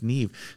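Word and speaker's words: Niamh: It's actually an Irish name. – Okay. – I had to look up how to pronounce it, but I think it's pronounced Neve.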